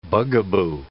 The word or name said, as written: bugaboo